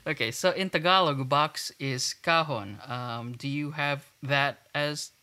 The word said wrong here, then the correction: kahon: So it's the emphasis is in the second syllable.